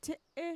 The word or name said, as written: txe'e